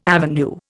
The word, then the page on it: avenue: Indian